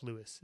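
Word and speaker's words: LUIS: It's called Microsoft Lewis is the way to pronounce it.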